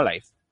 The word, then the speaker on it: live: Uno, si se usa como verbo, entonces su pronunciación es live. Muy bien. Dos, si se usa como adjetivo, entonces se pronuncia como live. En el caso de Xbox, live, su uso es como adjetivo, así algo así como Xbox en vivo o en directo.